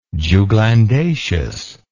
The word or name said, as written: juglandaceous